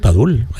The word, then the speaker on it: Padul: Tengo también Padul, Padul, sí, no sé si lo digo bien, perdonadme. – Sí, sí, Padul, Padul, en Granada.